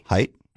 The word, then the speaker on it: Heydt: H-E-Y-D-T. – Height? – Is that how you say it? – Yeah. – Okay. Because it looks like hate. – Yeah. – Because hay with D-T on the end.